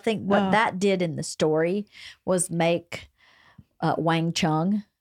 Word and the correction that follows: Wang Lung: Wang Lung, I think. – Sorry. – I don't know how to pronounce it. – Thank you, Wang Lung.